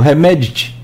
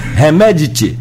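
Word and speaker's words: Hemedti: É, Mohamed Hamdan Dagalu, mais conhecido como Remedit. Remedit ficou mais fácil, que me lembra assim um Remedit é a pronúncia mais, mais próxima do correto, vamos dizer assim.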